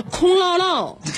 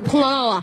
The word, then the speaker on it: 空落落: 那个好像我多音字错了啊，那个字儿音音那叫空落落，空落落啊，你先走吧。